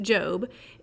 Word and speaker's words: Job: Quick insert, Job, it's spelled Job, but pronounced Job, is the 18th book of the Bible.